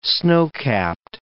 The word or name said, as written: snowcapped